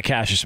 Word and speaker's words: Cassius: You know, and and Cassius looking at is that how you say it, Cassius? Yeah, Cassius Marsh.